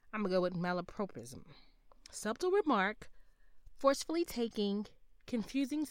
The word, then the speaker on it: malapropism: And um, let's hear the correct pronunciation. Malapropism. I said prop, they said prop.